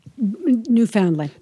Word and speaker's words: Newfoundland: Newfoundland. – Newfoundland ponies, who apparently there are not a lot of them left in the world. And we have Emily Chakowski coming on to talk to us about Newfoundland ponies.